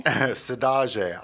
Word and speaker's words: saudade: Sadage. I thought it was saudade. – Yeah. – But then when I learned about, it's a Portuguese word that it's sadage.